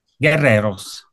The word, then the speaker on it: Guerreros: Guerreros, Guerrero. – che comunque è un guerrero, no? – Guerreros. – Ah, Guerreros, perfetto, avevo detto bene alla prima, ok.